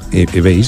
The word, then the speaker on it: eBay: Hace una foto para eBay. ¿Se dice así? – eBay, sí, muy eBay.